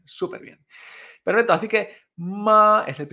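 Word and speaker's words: ma (妈): En este caso, primer tono es esta línea horizontal y se pronunciaría ma, ma. Repite conmigo. Ma, Perfecto, si te fijas, es como un tono agudo, ¿vale? Y continuo. Ma, es como el sonido de, un tren cuando pita.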